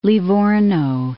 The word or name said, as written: Livorno